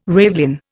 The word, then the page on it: ravelin: Indian